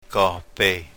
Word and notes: Kohpey: yurok

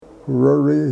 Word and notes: ku rori: Yurok

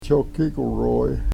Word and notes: chokikweroy: Yurok

